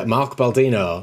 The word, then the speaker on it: Baldino: So joining me for episode, checks notes, episode 8, is Mark Baldino. Did I pronounce that right? – You did. Well done.